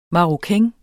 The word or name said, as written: maroquin